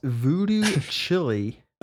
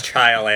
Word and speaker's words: Voodoo Chile: It's Voodoo Chili. – It's pronounced chile, actually. It's like this. It's like a chile.